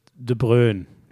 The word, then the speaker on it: De Bruyne: Kevin De Bruyne heißt, spricht sich selbst übrigens wirklich De Bruyne.